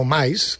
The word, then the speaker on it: MICE: Bueno, hay un turismo que se llama turismo MAIS, turismo MICE, que se escribe, que al final son personas que bueno, pues van para trabajar, pero conocen el entorno, conocen la localidad.